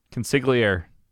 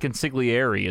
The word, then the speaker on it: consigliere: Consigliere.